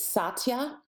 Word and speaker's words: Satya: The word it's Satya. It's spelled S-A-T-Y-A.